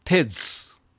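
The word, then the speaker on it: 'tis: それから何度か出てきますが、テズ、エテーズを短縮しますと、一般的には IT'S で It's と発音することが多いんですが、ここにありますように T'IS となった場合の発音は Tez 優勢になりますね。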